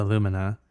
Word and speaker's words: Lumina: There was a Korean math professor who drove a Chevy Rumina, the Lumina. The best comment I heard was, if you know you can't pronounce an L, why would you buy a Chevrolet instead of a Honda Accord?